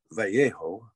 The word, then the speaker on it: Vallejo: But a man named General Mariano Guadalupe Vallejo is how people pronounce it here.